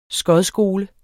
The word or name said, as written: skodskole